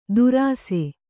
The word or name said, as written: ದುರಾಸೆ